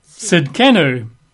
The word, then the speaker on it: Tzidkenu: or the Hebrew is Jehovah Tzidkenu. Now, Tzidkenu is usually spelled T-S, that's its sound, T-S-I-D-K-E-N-U, Tzidkenu. Occasionally you see it spelled with a Z at the front, Z-I-D-K-E-N-U, Tzidkenu, Jehovah Tzidkenu, which means Jehovah our righteousness.